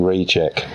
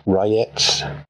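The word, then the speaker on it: Rejec: Now, I've been saying reject. It's clearly not reject. R-E-J-E-C. I want to apologise for that, as I understand that it's pronounced Rayets.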